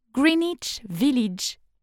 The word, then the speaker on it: Greenwich Village: Mieux encore, je vais vivre dans Greenwich Village, qui se prononce Greenwich Village et qui n'est pas du tout le village d'une Green Witch, d'une sorcière verte.